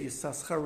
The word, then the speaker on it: Yisachar: Um, one, exa- one classic example is the name Yisachar, which is one of the 12 tribes, is actually spelled with two sins, two S's, right? And so, in, and so you would normally be, if you would kind of read it as spelled, it would read as Yisachar. But we don't read it Yisachar, we read it Yisachar.